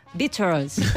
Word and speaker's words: Beatles: Pero fíjate que habla con una persona de lengua inglesa, ¿no? Anglosajona, y dice Beatles. ¿Cómo? Beatles. O sea, que en realidad Mikkel lo ha pronunciado muy bien.